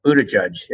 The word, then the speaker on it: Buttigieg: And I must say, I saw this young mayor, Buttigieg, as you told me his name is pronounced, who was the mayor of South Bend.